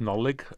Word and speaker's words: Nollaig: So, you are going to tell us, please, all about the word for Christmas, so nullig in modern Irish, and what were the words used for Christmas in medieval Ireland? – Well, the modern Irish pronunciation is nollig, uh, but the, in Old Irish uh, it still had another sound.